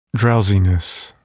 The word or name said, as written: drowsiness